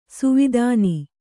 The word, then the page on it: ಸುವಿಧಾನ: kannada